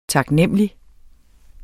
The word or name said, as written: taknemmelig